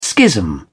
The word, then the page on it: schism: British English